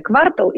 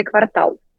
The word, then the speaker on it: квартал: Я поинтересовалась и выяснила, что существует убеждение, что когда речь идет о городском квартале, то ударение падает на второй слог – квартал. – Так. – А вот если это четверть календарного года, то на первый – квартал.